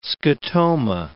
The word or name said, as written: scotoma